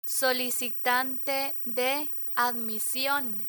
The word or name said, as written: solicitante de admision